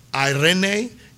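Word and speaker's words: eirene: So this word peace in particular is a word called Irene, and it's E-I-R-E-N-E. So everybody say Irene. Irene. Yes, it looks like Irene, but that's actually how it's said.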